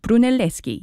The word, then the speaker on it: Brunelleschi: which was designed by Filippo Brunelleschi. I hope I'm saying that right. Brunelleschi, yes. And I have to say, it's one of the most impressive buildings that I've been lucky enough to see in person.